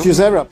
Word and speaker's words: Josera: ¿Cómo se dice? – Yosera.